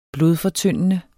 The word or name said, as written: blodfortyndende